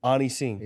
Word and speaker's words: Ani Singh: Ani Singh.